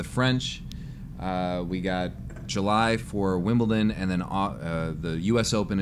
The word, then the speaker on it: Wimbledon: Not Wimpleton. That's not how it's said. It's Wimbledon.